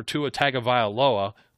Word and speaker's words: Tagovailoa: Well, Ryan Fitzpatrick, who's been coming in and out for Tua Tagovailoa.